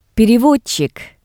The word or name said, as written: perevodtsik